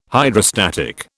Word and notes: Hydrostatic: British English

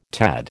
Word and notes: tad: American English

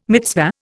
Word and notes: mitzvah: Indian

